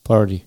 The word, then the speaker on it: party: А в американском варианте вот эта буква «Т» в слове «party», она почему-то становится звонко и меняется на что-то близкое к «ди». Поэтому читается как «party», «party», «party». Не знаю, как точно, но вот так оно читается.